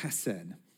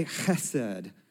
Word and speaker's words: chesed: Mercy, this word mercy here is actually the Hebrew word chesed. And you got to have the in there to say it right. So go ahead with me. Can you say chesed?